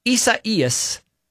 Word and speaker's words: Isaías: Okay, so apparently Isaías is the Spanish and Portuguese form of the biblical name Isaiah.